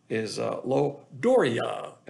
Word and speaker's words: loidoria: That's in the King James, of course, but the Greek word is loidoria, and that's spelled L-O-I-D-O-R-I-A.